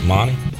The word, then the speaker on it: Monty: Is that how you pronounce it? Monty? Yeah. Full Monty.